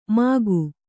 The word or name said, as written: ಮಕ್ಕಿ